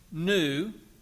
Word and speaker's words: nu: Interestingly enough, though, the World Health Organization chose to skip two letters of the Greek alphabet, nu and xi. They chose to skip nu which is spelled N-U, because it sounds like the English word new, N-E-W, and that could be confusing.